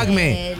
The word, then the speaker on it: acmé: Es marca acmé. Es una marca. – Trucho. – Camino. – Pero con acento en la E, acmé.